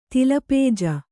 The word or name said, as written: ತಿಲಪೇಜ